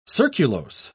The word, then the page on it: surculose: British English